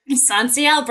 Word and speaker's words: SONSEIL: Am I saying that right? – SONSEIL, Brian, you got it.